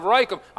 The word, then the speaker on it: Rachab: And when Jehonadab came out there, and it says he was a son of Rahab, or Rachab, or I pronounced that wrong. He was a son of Rachab.